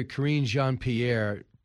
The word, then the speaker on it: Karine Jean-Pierre: But see, Charlie, first I want to let you hear Press Secretary Karine Jean-Pierre – push back. – I admire your pronunciation. That's very impressive.